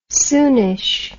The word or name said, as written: soonish